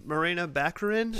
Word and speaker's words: Morena Baccarin: Morena Baccarin.